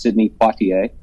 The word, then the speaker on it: Sidney Poitier: And I'm going to – forgive me if I don't pronounce his name properly, but I believe it's pronounced Sidney Poitier.